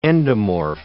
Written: endomorph

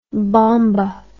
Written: bomba

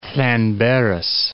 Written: Llanberis

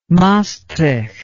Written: maastricht

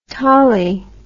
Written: tollie

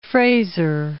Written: Frazer

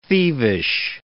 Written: thievish

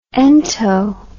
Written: ento

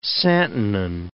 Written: santonin

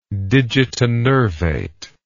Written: digitinervate

